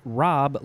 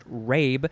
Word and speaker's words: rabe: Rob looks like it's pronounced rabe, but it's not. It's rob. R-A-B-E.